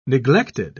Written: neglected

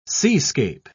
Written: seascape

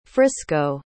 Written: frisco

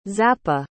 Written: zappa

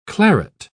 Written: claret

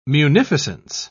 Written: munificence

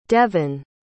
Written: deven

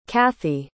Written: cathie

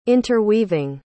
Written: interweaving

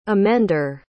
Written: emender

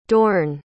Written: Dorn

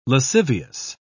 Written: lascivious

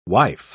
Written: wife

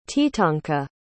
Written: teetonka

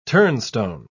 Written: turnstone

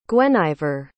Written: gwenyver

